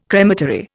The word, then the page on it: crematory: Indian